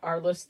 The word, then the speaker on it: Aurelius: Arlis, luckily, A-R, like Arlis. – A-U-R-E-L-I-U-S.